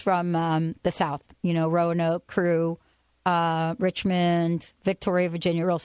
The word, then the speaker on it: Roanoke: Roanoke. I know I said that right, because you know why? Because my peeps are from Virginia.